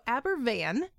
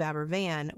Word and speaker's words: Aberfan: So Aberfan, Wales, which is spelled A-B-E-R-F-A-N, but pronounced as if that F is a V, is a Welsh coal town in the Taff Valley, and it basically only existed because of coal mining.